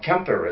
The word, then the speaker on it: Quimper: Well, no, Kemper is how they pronounce it. – Okay. Yeah, because of Breton.